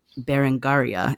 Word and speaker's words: Berengaria: And it reminds me of the opening anecdote of your book, which is about Berengaria. Is that how you pronounce her name? That's correct.